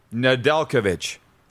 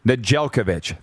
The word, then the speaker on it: Nedeljkovic: Nedeljevic? How do you say it? Nedeljkovic. From Carolina to Detroit for a third, and Jonathan Bernier's unrestricted free agent rights. Nijelkovic, thank you.